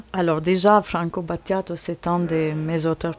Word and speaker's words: Franco Battiato: Alfonsina, cette musique, donc c'est de Franco Battiato, j'ai bien prononcé.